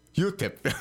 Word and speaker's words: UTEP: donde nos escuchan nuestros colegas de la Universidad UTEP. ¿Lo pronuncié bien? – UTEP.